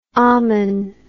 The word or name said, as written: Amun